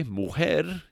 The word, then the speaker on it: mujer: Okay, mujer is spelled M-U, that's M as in Mary, U, J-E-R, mujer.